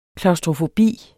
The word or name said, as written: klaustrofobi